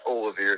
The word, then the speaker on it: Olivier: It's Olivier, if you want to get technical, but I've been called worse things than Oliver or anything else like that.